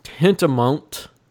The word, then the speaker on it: tantamount: But there are actions tantamount. Is that how you say it? – That's how you say it.